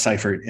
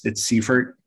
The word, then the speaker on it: Seifert: It's spelled Seifert, but it's pronounced Seifert, and I pronounce it differently. Like if I'm at a pharmacy, I've just given up and I just say, oh, it's Seifert, S-E-I-F-E-R-T, because then I only have to spell it once. And if I say Seifert, which is, how it is pronounced in German, then, I have to spell it a couple times.